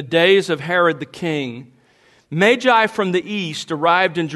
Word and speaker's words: Magi: Now, let me just at the beginning say to you that I'm going to pronounce it that way throughout this sermon, Magi, because, as I learned a few years ago, that is the only pronunciation in the dictionary.